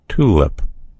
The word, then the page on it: tulip: American English